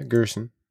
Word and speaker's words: Girsan: I don't know how to pronounce this. G I R S A N. Grinson? – Gerson? G I R S A N? – Yeah. – Yeah, Gerson.